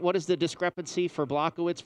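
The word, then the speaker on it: Błachowicz: The other one, I think I'm pronouncing right, Blachowicz. – Yeah, Jan Blachowicz.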